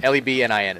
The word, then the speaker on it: Lebanon: Lebnin is how it's pronounced. It's like Louisville.